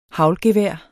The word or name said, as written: haglgevær